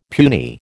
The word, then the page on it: puny: American English